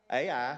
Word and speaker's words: Ai: But then the next town they'd come up to was the little town of 2 letters, AI. And that's how you pronounce it.